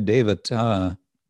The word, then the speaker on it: devata: Deva can mean God or it can mean the devas, the devata, the divinity. The word devata, with that T, long A at the end, is actually an abstraction.